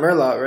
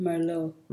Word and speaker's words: Merlot: It's not Merlot, right? – No, you don't say the T. – How do you say it? – Merlot.